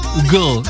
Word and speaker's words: Hugel: En realidad, Hugel es francés, así que la pronunciación es como Hugel, Hugel, algo así.